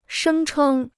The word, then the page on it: 声称: shēng chēng